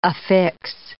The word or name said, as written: affix